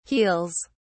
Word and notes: heels: British English

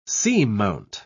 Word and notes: seamount: British English

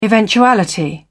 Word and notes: eventuality: British English

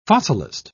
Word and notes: fossilist: British English

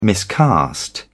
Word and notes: miscast: British English

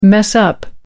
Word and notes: mess-up: American English

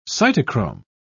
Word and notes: cytochrome: British English

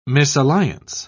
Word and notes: misalliance: British English

